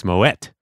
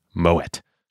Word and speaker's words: Moët: It's not Moët, it's Moët. – Yeah, what is the deal with that? – The Moët family, even though they are French, it is a Dutch name, so you don't pronounce it like in French. You pronounce it as in Dutch with the hard T, Moët.